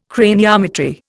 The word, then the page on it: craniometry: Indian